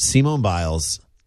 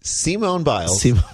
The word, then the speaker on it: Simone Biles: But Simone Biles. – Simone Biles. Way to put the emphasis on the wrong syllable. – Simone Biles.